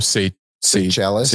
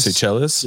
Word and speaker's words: Seychelles: Oh, Seychelles. – Seychelles.